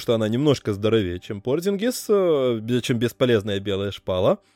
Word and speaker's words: Порзингис: И на самом деле, вот без шуток, я бы его не глядя бы махнул бы на Парзингиса, если была бы возможность. – Мне кажется, многие болельщики Далласа тоже с тобой согласятся все-таки особенно в текущем состоянии Порзингиса, как это правильно говорить именно Порзингис, Марканен выглядит как минимум интересным.